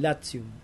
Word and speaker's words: Latium: ¿Cómo se dice Latium si lo pronunciamos bien en latín? Latium.